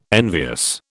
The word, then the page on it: envious: British English